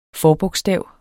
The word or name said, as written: forbogstav